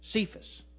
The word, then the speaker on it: Cephas: Cephas.